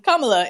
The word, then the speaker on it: Kamala: It's Kamala. In South Asian names tend to put the emphasis on the first syllable. So Kamala, yeah.